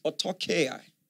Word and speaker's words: autarkeia: The Greek word translated sufficiency is autarkeia.